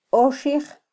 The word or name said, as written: oxij